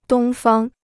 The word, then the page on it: 东方: dōng fāng